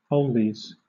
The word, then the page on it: oldies: British English